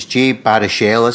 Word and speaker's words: Jay Baruchel: So, and hopefully I pronounced his name correct, it's Jay Baruchel, isn't it? – Yes, indeed, yeah.